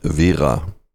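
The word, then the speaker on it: Wera: The correct pronunciation in German, and I'm German, is Vera. I know Anglophile people will normally say Vera because they roll the R, but that's fine with us. But Vera is the correct word.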